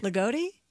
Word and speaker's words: Loogootee: L-O-O-G-O-O-T-E-E. – Ligoti? – Ligoti, Indiana.